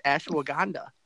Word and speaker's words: ashwagandha: I just said it wrong. It's Ash. What? Yeah, no, it's Ashwagandha.